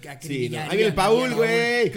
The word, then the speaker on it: Paul: O sea, No, si es, ponle acento en la U, por favor. Es Paul y va a ser un gran futbolista.